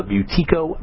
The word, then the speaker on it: Buteyko: We're talking to Sasha Yakuleva, an expert in the Buteyko breathing method, and that's spelled B-U-T-E-Y-K-O, Buteyko breathing method, and Russian Healing Arts.